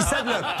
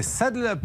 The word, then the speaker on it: Saddle Up: Pas du tout, il dit s'adlock. Non, non, mais attention, il dit saddle David Christie et Saddle Up. Voilà, c'est ainsi qu'il faut le prononcer. Saddle Up.